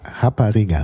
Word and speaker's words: rapariga: Also Portugiesisch Rapariga, also ist ausgesprochen, das R mit dem H ausgesprochen im Brasilianischen, ist in Brasilien die Prostituierte.